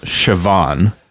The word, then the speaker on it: Siobhan: but her name was Siobhan, spelled like Sean, but with a V in it.